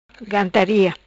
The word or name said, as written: Gantaria